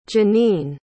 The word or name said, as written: jennine